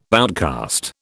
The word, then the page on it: outcast: British English